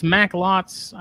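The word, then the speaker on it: Macklot's: I'm pronouncing Mac, it's Macklots. I just shortened the O. Macklets.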